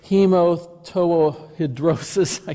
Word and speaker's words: hematohidrosis: I've tried to pronounce this word, hematohidrosis.